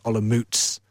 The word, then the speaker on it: Olomouc: by telling them that team is pronounced Sigmund Olomouc.